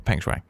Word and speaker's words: Peng Shuai: It's Peng Shuai, I believe it is. Almost like Feng Shui, mm-hmm. But Peng Shuai.